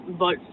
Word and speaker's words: Volf: Det er sådan, at i dag, der staves Karen Wolf. V-O-L-F. – Ja. – Og det udtales Wolf.